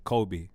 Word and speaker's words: Kobbie: You say his name properly. – Sorry, Kobbie.